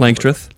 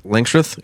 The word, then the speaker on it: Langstroth: So I am running Langstrom. Is that how you spell it? – Langstroth. – Langstroth. Yeah. Langstroth hives.